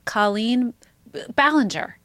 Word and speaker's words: Colleen Ballinger: Colleen Ballinger.